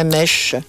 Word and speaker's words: Mensch: Est-ce que je prononce bien Mensch ? – Mensch.